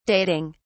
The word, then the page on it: dating: British English